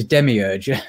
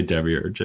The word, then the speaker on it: demiurge: It took a long time to learn to pronounce that word, but it's demiurge. Yeah. – Well, I mean, it's demiurgos in Greek. You can do whatever you want with it. I usually say demiurge in English.